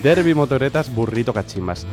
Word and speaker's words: Derby Motoretas Burrito Cachimbas: A ver, un momento, ¿cómo se dice? – Derby Motoretas Burrito Cachimbas.